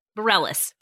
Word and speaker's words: Bareilles: Sarah Bareilles. How do you say her name? She's a singer. She's an actual singer. – Bareilles. – Bareilles. Yes.